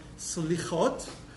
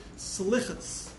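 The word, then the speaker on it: Selichot: And so I want to talk about the concept of Slichot, or in Ashkenazic pronunciation, we did a class a few weeks ago about the different forms of pronunciation, Slichas is the way Ashkenazic Jews pronounce it, or Slichot.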